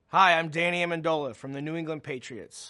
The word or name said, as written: Danny Amendola